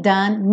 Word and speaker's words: done: Então, and the day is nearly done, nearly done, ok? Agora o done, uma sílaba só, done. Esquece esse E final.